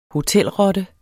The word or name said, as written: hotelrotte